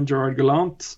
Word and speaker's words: Gerard Gallant: Uh, Gerard Gallant. Ja. Vi skal ta det amerikanske uttalen av hans fransk-canadiske navn. Ja.